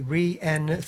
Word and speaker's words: Rhiannon: Rhiannon, so it needs to be Rhiannon, three syllables.